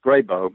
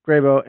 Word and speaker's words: Grebo: The two groups that they encountered, the two different places, is it the Gribo? Is that, am I pronouncing that correctly? – Yes, Gribo. Mm-hmm. – Gribo and the Mokambwe greeted them quite warmly,